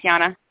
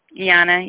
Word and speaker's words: Yanna: It's Yana. – Tiana? – Yana, yeah. – Yana? – Yana, yeah. If I can spell it, Y-A-N-N-A.